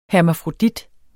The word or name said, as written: hermafrodit